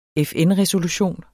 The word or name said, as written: FN-resolution